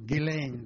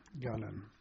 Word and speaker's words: Gullane: And we were sent to a lovely seaside resort in Scotland, obviously, Gullin, or Gillane, the Scots used to call it, which was about 20 miles east of Edinburgh. – How do you spell that? – Gullin is G-U-L-L-A-N-E, Gullin.